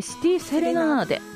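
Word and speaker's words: 長崎小夜曲: 本日お越しいただきました矢野さんからのリクエスト曲ですが、さだまさしの、長崎庄屋曲と書いてシティセレナーデと読むということで、この曲を聴きながら矢野さんとはお別れです。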